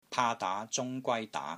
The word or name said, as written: PAA3 DAA2 ZUNG1 GWAI1 DAA2